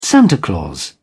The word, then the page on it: santa claus: British English